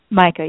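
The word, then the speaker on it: Maika: Is it Micah? – Micah, yes.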